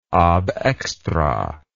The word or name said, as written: ab extra